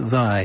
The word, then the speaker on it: Via: And his last name is spelled V-I-A, like Via, but he pronounces it Vai.